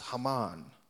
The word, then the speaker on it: Haman: Can you say Haman? Uh, in, the orig- in the original text is pronounced Haman, the Agagite, exalting him and setting his position above that of all the officials who were with him.